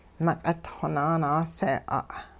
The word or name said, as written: meq'itxwo naanaasee'a'